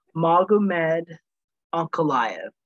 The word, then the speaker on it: Magomed Ankalaev: Sorry, I'm going to try to see if I can do this. Pronounce it properly. Magomed Ankalaev. There we go.